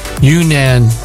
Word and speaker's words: Yunnan: Anyway, the mushroom grows in China's Yunnan province.